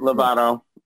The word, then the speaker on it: Lovato: Lovato.